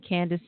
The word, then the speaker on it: Candace: And Candace is spelled C-A-N-D-A-C-E.